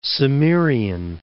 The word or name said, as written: Cimmerian Bosporus